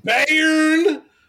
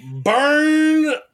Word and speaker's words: Bayern: Oh. Pronounced Bayern. Bern Munich. – Fuck you. – For those who don't know this joke, we play the game World of Warships. And there is a level 5 German battleship named the Bayern. The Bayern. And Mike knows, he's pretty well versed in German. And I intentionally have been saying it wrong for, let me count, 4 years? – Years now, just to fuck with Mike. Because I know he doesn't like when I say it wrong.